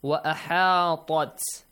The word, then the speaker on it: وَأَحَاطَتْ: Wa ahatat. Ahatat. – Yes. – Make sure this ta, the letter ta, should not be so much obviously pronounced. Okay, like kind of this. Wa ahatat. Sound no.